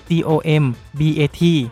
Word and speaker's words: Mortal Kombat: นได้เกมต่อสู้แบบทัวร์นาเมนต์ชื่อ Mortal Kombat ที่สะกดคำว่า Combat ด้วยตัว K ทำไมถึงสะกดแบบนั้นเอ็ดบูนได้เปิดเผยภายหลังว่าระหว่างที่กำลังพัฒนาเกมก็มีการคิดชื่อออกมาตั้งหลายชื่อแต่ก็ยังไม่ค่อยเป็นที่พอใจจนกระทั่งมีหนึ่งในทีมงานไปเขียนบนกระดานว่าชื่อ Combat COMBAT